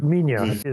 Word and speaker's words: Minya: So it's Minya is the actual spelling, but it's often spelled, for decades, was spelled in English as Manila. So like Godzilla, but Manila. So, but Minya is a more correct pronunciation.